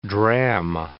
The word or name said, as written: dram